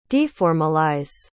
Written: deformalize

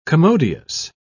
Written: commodious

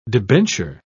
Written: debenture

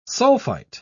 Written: sulfite

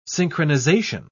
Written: synchronization

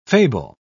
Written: fable

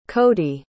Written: codey

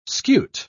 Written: scute

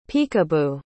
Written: picabo

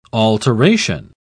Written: alteration

